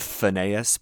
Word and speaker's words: Fiennes: And conveniently, we have two diabolical British character actors, and probability says if there is a Fiennes brother or a Phineas brother, someone told me that it's pronounced like Joseph Phineas, but I'm just going to say Fiennes.